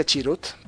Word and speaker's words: Chirrut: Chirrut Imbue, se pronuncia Chirrut, por cierto.